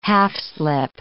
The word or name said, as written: half-slip